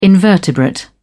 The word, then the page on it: invertebrate: British English